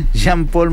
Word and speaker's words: Janpol: También trajeron al jugador Jean-Paul Morales, se escribe J-A-N-P-O-L, no Jean, sino que Jean-Paul, pero se pronuncia Jean-Paul Morales, de las Comunicaciones de Guatemala.